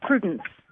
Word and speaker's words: Prudence: Prudence. – I heard a Susan. – Prudence. – How do you spell that? – P-R-U-D-E-N-C-E.